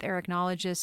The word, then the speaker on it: arachnologist: I've been speaking today with arachnologist Susan Kennedy.